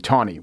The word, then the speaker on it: Taney: And he went on to say, you're supposed to pronounce Taney Tawny, which I also knew.